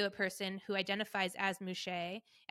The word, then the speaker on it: muxe: They have male, female, and then they have a gender that's spelled M U X E, which is pronounced mouche.